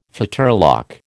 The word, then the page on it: fetterlock: American English